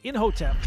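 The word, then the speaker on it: Imhotep: Inhotep, credited with the first pyramid, and he made it because he really hated his wife's nagging.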